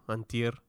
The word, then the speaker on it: antier: Did I say it right? – Like, a little. Antier. – Are you using like un? – An. – An. – Di. – Di. – Er. – Er. – There you go.